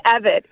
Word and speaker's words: Evette: Before the break, we were talking to the Lieutenant Governor of South Carolina, Pamela Yvette. Uh, I hope I'm not butchering your, your, your name, um, Lieutenant Governor. it's Evette.